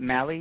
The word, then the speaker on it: Mali: M-A-L-I, Mali. – How do I say it? Mali, you said? – Mali, yeah.